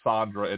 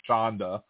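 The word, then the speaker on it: shanda: It's not Sandra. It's Shonda.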